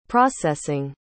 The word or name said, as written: processing